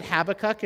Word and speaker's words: Habakkuk: Some people will pronounce it Habakkuk. If you want to pronounce it in Hebrew, it's Chavakuk, but and I've just butchered that, if you didn't know.